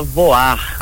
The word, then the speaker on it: Voir: é Cia Voar. Ah. Voar. – Se escreve Voir, né? Inclusive o nosso Instagram é Cia Voir de Teatro, mas a gente pronuncia voar.